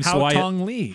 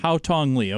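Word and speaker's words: Haotong Li: Al Tong Lee. – Bless you. Say it again one more time. – Haotong Lee. – Haotong Lee. Okay, now at least I know how to pronounce it.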